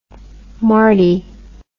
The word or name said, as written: marty